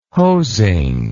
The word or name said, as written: hosing